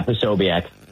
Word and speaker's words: Posobiec: Yeah, Posobiec.